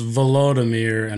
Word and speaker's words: Volodymyr: Well, it's Volodymyr and Vladimir. – Oh, that's on me. – Voldemort? – Almost Voldemort. Volodymyr.